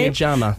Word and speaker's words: Maya Jama: Maya Jama.